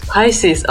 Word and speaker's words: Pisces: Pisces, oh yeah.